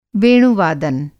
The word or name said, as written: वेणु-वादन